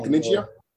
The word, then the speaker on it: Caniggia: Is that how you pronounce it? Caniggia? – Yeah.